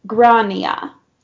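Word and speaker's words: Gráinne: The correct Irish pronunciation is Grania.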